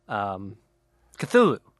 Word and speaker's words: Cthulhu: I mean, is that how you pronounce it? – That's how I've always said it. – Okay. – You say like Cthulhu or something. – Wow. – Which sounds like a labored sneeze.